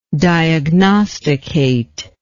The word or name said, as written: diagnostication